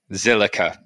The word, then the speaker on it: Zilliqa: And of course, last but not least, making a debut today is co-founder of, I never know how to say this, Zilla? Is it just Zilla? The Q always confuses me. – Zillica. – There we go.